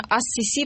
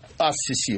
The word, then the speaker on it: Ас-Сиси: А наш герой Абдель-Фатах Саид Хусейн Халил Ас-Сиси, а именно Ас-Сиси произносится по-русски, потому что по правилам арабской филологии... – Ну, по-арабски... Ас-Сиси, потому что С – это солнечный харф, артикль редуцируется, харф удвояется. Правильно, Ас-Сиси.